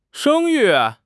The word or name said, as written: 生育